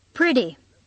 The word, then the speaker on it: pretty: Pretty. – Pretty. – Pretty. – 发现没有，当我们说快的时候 T 变成了 D. British people say pretty. Americans say pretty.